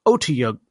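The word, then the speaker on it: Otyugh: Standing at the bottom of the cistern, Tiberius and Kashal and Keyleth stare down a giant Otiug. How do you say it again? – You said it right.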